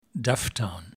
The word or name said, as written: Dufftown